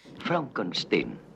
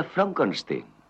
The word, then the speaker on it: Frankenstein: Frankenstein. ¿Me toma el pelo? No, se pronuncia Frankenstein.